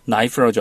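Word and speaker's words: knife: 나이프라고 하죠. 거기도 K로 시작을 합니다. 근데 네. 그 K는 발음을 안 해줍니다. – 네. – 그런 단어가 몇 개 있는데 이 단어도 마찬가지입니다. 알다라는 뜻인데 K-N-O-W 이렇게 써 있는데 크노 이렇게 발음하지 않습니다.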